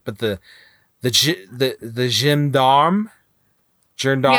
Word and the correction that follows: Jem'Hadar: Yeah, the Jem'Hadar.